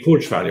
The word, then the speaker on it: Kurzweil: Ray Kurzweil. Jag kan inte uttala. – Ray Kurzweil, ja just det.